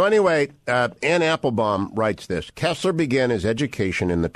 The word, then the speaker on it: Koestler: I believe she is a left, but I don't know, reviewed Michael Scammell's biography of Koestler,